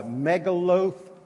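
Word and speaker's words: Megillot: Remember that part about the megaloth? That's how you say it. Long O-T, don't say the H at the end.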